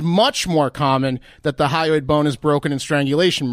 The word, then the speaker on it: hyoid: And one of the bones that was broken was the hyoid bone. I think I'm pronouncing that correctly. – H-Y-O-I-D.